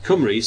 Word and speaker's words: Cymru: Now, Cymru, C-Y-M-R-U, U is being pronounced E.